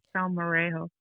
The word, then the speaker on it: salmorejo: I haven't seen And I don't know if I'm saying it right. Sal Marejo. Okay. S-A-L-M-O-R-E-J-O.